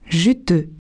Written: juteux

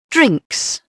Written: drinks

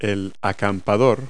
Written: camper

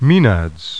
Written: MAENADS

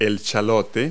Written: shallot

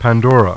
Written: Pandora

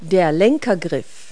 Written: handgrip

